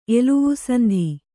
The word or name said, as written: ಎಲುವುಸಂಧಿ